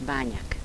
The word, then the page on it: banjak: dialetto croato di Lukezi